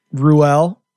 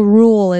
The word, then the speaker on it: Ruel: How many different wrong pronunciations have you got? – Oh, there's so many. I think Rule is the most popular, though. – Rule. So not Rule. That's like the Mississippi pronunciation.